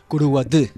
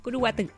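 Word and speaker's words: Curuguaty: Curuguaté. – De Curuguaté. – A ver, corríjame usted. ¿Cómo? – Curuguaté. – Bien. Es como que termina con la T.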